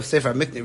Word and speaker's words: Sefer HaMikne: The Sefer HaMakne goes a step, Sefer HaMikne really should be pronounced.